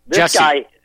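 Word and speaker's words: Jussie: But also, not only that, this Juicy Smollett guy, does Juicy? – Jesse. – Is it Juicy or Jesse? I don't know how do you pronounce his name. – No, Jesse. Juicy, isn't it? Listen, Jesse.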